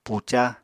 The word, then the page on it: puca: apatani